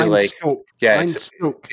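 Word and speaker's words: Einstök: How do you pronounce the two little dots over an O? – It's usually Einstock. It's a long O.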